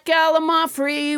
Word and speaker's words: Gallimaufry: Let's never forget Gallimaufry, which I pronounced wrong until nobody James Hyder corrected me.